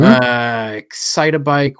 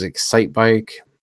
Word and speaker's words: Excitebike: Excitabike, which everybody else pronounces Excitebike.